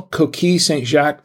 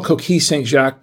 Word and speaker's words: Coquille Saint-Jacques: National Coquille Saint-Jacques Day. I said that wrong. It's National Coquille Saint-Jacques Day.